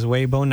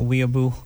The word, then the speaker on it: Weibo: Yes, it is Weibo, not Weibo. as many people might just overall generalize it to be due to its foreign-sounding name.